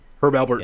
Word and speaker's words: Alpert: For some reason, I used to put a P in it. It'd be like Herb Alpert.